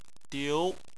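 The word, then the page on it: diu: tone 2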